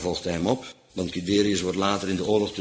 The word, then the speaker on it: Guiderius: Bovendien een koning, ja, hoe moet ik dat nou maar uitspreken? Guiderius, G-U-I-Derius.